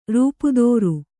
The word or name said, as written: ರೂಪುದೋರು